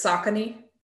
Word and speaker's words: Saucony: First of all, can we just briefly pause and just mention that it's Saucony? – This Saucony.